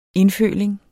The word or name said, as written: indføling